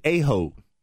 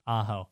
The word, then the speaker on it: Aho: Sebastian Aho. – Yeah. – That's not correct? – It is not. – That's not the proper pronunciation? – No, it is Sebastian. Aho. Ah. That's how it's pronounced.